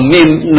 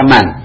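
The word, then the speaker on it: min (مِنْ): Awas, kalau min nun ditulis sebelum aina, jangan dibaca man, tapi min. Ya, kalau mim nun sebelum aina, jangan dibaca man, tapi pasti min itu dibacanya itu.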